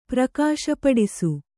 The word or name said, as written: ಪ್ರಕಾಶಪಡಿಸು